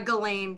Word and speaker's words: Ghislaine: and is it Ghislaine? I'll never say her name right. – Yeah, Ghislaine Maxwell.